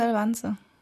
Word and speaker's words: Elvanse: Spricht man das echt Elvanse? – Bei uns in der Klinik sagen sie Elvanse.